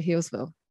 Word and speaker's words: Healesville: Is it how you say it? Healesville? – Yeah, Healesville. Yeah.